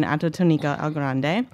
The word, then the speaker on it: Atotonilco El Grande: So you were born, like I said, and you helped me with the pronunciation, Atotonilco El Grande.